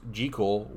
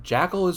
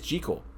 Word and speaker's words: Jekyll: The only version where Dr. Jekyll's name is pronounced Jekyll, which, Justin, is the correct pronunciation. Jackal is wrong. It is Jekyll.